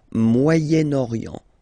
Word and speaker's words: Moyen-Orient: So it's interesting here because normally if you would have only this word here, you would pronounce it moyen, moyen, okay? But then as you've got this vowel after, okay, you've got to make the liaison between the two, le Moyen-Orient, Moyen-Orient.